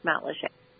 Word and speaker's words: Matlacha: Every day when I left my house, I had to drive through Matlacheux. It's spelled Matlacha, but It's pronounced Matlacheux. Anyway, so that area was, like, destroyed.